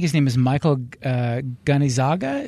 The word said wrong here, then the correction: Gazzaniga: is that how it's pronounced? – Or Gazzaniga. – Gazzaniga.